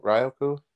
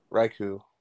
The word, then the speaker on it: Raikou: I always butcher his name. Ryoku? – Raikou? – Raikou. – Yeah.